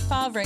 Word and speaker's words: Favre: Brett Favre scandal explained. – It's actually pronounced Favre.